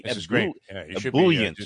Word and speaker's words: ebullient: Yes, like a French bouillon. It's an ebullient.